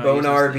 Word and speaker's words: Bonar: B-O-N-A-R. – It's bonar, which is what sells me.